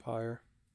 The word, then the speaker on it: pyre: P-Y-R-E. – Pyre. – Pyre. The Y threw me off. I'm like, how the hell am I supposed to pronounce that?